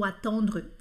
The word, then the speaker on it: attendre: Então, basicamente, esse en do attendre, do verbo esperar, é on, ele é redondo, tá? O céu da boca fica redondo. Attendre, attendre, esperar.